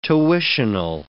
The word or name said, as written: tuitional